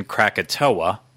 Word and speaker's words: Krakatoa: That is because it was spelled Krakatau, which I assumed that was going to be Krakatau because that's how I would phonetically say it out. Someone pointed out to me in the comments that that's actually the Indonesian way of spelling Krakatoa, which, of course, I know Krakatoa.